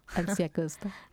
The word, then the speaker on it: Alci Acosta: Alci Walchi, je sais pas comment on prononce, Acosta. – Alci Acosta.